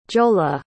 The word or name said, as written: jola